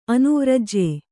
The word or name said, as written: ಅನುವ್ರಜ್ಯೆ